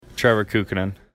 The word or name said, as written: Kukkonen